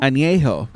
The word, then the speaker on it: Añejo: It's like A-N-E-J-O. Anjo? – Añejo. – I think that's what it is.